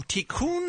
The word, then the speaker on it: Tikkun: And Nikki from Tikkun Holistic Spa.